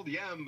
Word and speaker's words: YEM: Yeah, it's called Yem, but it's Yem because it's You Enjoy Myself.